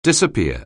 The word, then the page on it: disappear: anglais